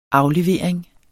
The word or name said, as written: aflevering